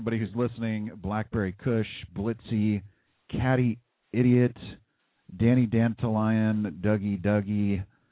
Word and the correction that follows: Dantalion: Hey, yo, his name pronounced Dantalion. – Dantalion.